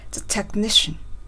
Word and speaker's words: technician: そしてその次、テクニシャンの T の発音を練習しましたね。 – The technician。 – テクニシャンっていう風にですね、 T に勢いをつけて発音すると非常に英語らしく綺麗になりますということで練習していきました。